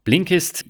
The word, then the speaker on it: Blinkist: Du hast mir doch letztens erzählt, du liest eine ganze Menge über, ja, Blinkist hießen die, oder habe ich das jetzt richtig ausgesprochen? – Genau, Blinkist lese ich jetzt schon seit, ich glaube, über 2 Jahren.